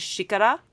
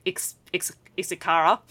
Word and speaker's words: xícara: Unlike the family name that has an X in it, which in Portuguese, in Brazilian Portuguese, is pronounced like a sh. So the word for cup in Brazilian Portuguese is xícara, not xícara, if I was pronouncing it with my really broken Australian Queensland accent.